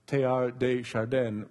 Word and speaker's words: Teilhard de Chardin: French priest and philosopher Teilhard de Chardin, Dominique had to help me with the pronunciation, he said this.